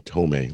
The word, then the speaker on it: Thome: For years, even before we acquired him, the pronunciation guide said the first baseman for Cleveland was Jim Thome. And yet I'd hear every announcer call him Thome, like Mel Torme.